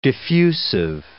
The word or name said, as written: diffusive